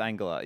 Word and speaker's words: Angela: No, it's not. It's Angela. You were pronouncing it wrong the whole time. – It says here Angela. – Yeah, no, it's a hard G.